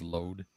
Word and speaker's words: Lode: This is L-O-D-E. – Oh, thank God. – Is that what it's called? Lode? – Yeah. Yeah. That's how it's said. That's how it's said. – That is how you pronounce that.